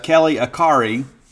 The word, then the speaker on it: Akari: Now, number one, wearing Chad Bumpus' number, is Kelly Akari. Rhymes with Atari. It looks a little more complicated than it is.